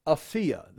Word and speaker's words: Apphia: But also, you've got Philemon and Apphia, or Apphia. It's pronounced both ways. I looked it up. I actually looked it up. It could be Apphia or just Apphia.